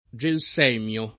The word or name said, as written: gelsemio